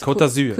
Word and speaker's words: Côte d'Azur: Côte d'Azur.